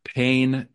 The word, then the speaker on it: Payne: except for a travel abroad program sponsored by Payne University. Yes, P-A-Y-N-E, but pronounced P-A-I-N, for which Fitger, exactly the wrong faculty member, is chosen, meaning coerced into, as teacher and chaperone.